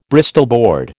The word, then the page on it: Bristol board: American English